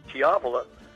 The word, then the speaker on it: Chiavola: The way I pronounce it is Kathy Chiavola, and the song, beautiful song, is 30 Years of Tears.